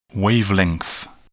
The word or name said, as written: wavelength